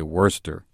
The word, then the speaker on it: Worster: Worster is spelled W-O-R-S-T-E-R in my spelling of it. – Okay. – And since I'm from Kansas, we say Worster. But it is Worster.